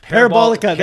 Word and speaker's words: Parabolica: Pair of Balsica. – Parabolica. – Parabolica. Thank you for correcting me.